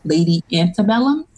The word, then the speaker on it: Lady Antebellum: And they have like huge stars and like, is it Lady Antebellum? Is that how you say it? – Yes.